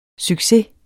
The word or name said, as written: succes